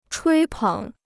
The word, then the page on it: 吹捧: chuī pěng